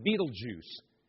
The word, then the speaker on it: Betelgeuse: It's called Betelgeuse, or G-E-U-S-E. Some pronounce it Betelgeuse.